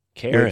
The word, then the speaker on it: Charon: I'm like, fuck, how do you pronounce Charon? And it's Charon. – Karen.